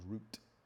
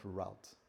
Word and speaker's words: route: If we decide to go down that route, like the Americans would say, but the proper pronunciation is route, not route, British.